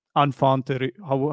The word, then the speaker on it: enfant terrible: He is also known for being a bit of a troublemaker and enfant terrible. How do we say that? Enfant is like a French word, enfant terrible or something.